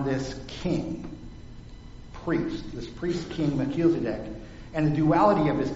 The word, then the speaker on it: Melchizedek: See, I even spelled it out phonetically there, because everyone has to. Melchizedek. Say that with me. Melchizedek. Now you got it forever.